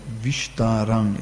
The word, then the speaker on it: Vistaram: Yeah, so one note on the Sanskrit, that Vistaram is pronounced T the S is like an S, normal S, vis.